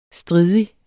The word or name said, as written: stridig